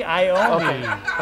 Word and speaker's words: Iommi: Tony Iommi.